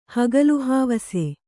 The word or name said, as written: ಹಗಲುಹಾವಸೆ